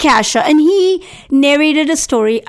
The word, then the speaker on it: Cassia: And then he asked me how I would pronounce the name spelled C-A-S-S-I-A. So I'm thinking, Cassia, and he said, no, it's Kasia.